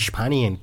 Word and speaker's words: Spanien: Si queréis pronunciarlo bien, solamente tenéis que decir Spanien. – Spanien. – Spanien, por eso se lleva también el...